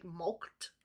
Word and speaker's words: mulcted: It's spelled here M-U-L-C-T-E-D, like mulked.